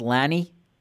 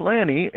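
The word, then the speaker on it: Lani: The Japanese horse is pronounced Lanny. – It's pronounced Lanny,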